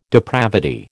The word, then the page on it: depravity: American English